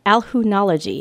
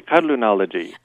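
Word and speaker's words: Qallunology: I'll try pronouncing it. I think my pronunciation would be a little bit better than yours. – Okay, sorry. – It'd be Kadlunology.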